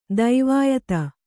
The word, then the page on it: ದೈವಾಯತ್ತ: daivāyatta